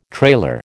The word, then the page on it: trailer: American English